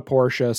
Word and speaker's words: Porsche: I recently spent all of that money on a Porsche, so thank you so much for all of the love and attention that you feed Ron's ego with. – And you said Porsche, so you said it right.